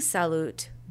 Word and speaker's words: salut: I'm saying salut, but it's spelled S-A-L-U-T.